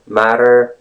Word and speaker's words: matter: It's flap T sound, which is pretty much same as the D sound. Matter, matter, matter. Or R, or something in between D and R, matter, matter, right? The next thing is the emphasis on the first syllable of the word matter, what's the matter?